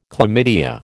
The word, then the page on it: chlamydia: American English